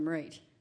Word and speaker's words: Mairead: Mairead. Breed. – Mairead and Breed? – Yeah, it's Irish for Margaret. Oh. It's pronounced like parade with an M, so Mairead.